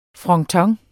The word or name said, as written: fronton